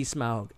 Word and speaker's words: Smaug: And, you know, understand, like, what's at stake here and kind of explaining what's going on with Smaug is Smaug. The correct pronunciation.